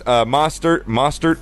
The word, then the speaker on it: Mostert: If you look at the running back core, Tevin Coleman, Raheem Morse, Mostert, I believe that's how you say it.